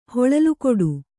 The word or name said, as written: ಹೊಳಲುಕೊಡು